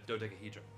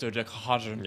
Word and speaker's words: dodecahedron: Now that I move my head, it's a dodecahedron. So it's like panels of pentagons. – It's actually pronounced dodecahedron.